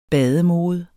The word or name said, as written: bademode